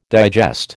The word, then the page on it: digest: American English